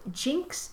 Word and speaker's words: Gynx: And the other purchase I made at the Fiber Festival was 2 skeins of Jinx yarn, and that's spelled G-Y-N-X.